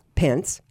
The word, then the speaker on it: Pence: Pence, Manoah Pence, but it's spelled P-E-N-C-E, and right. – I thought, that sounds the same as P-E-N-T-Z.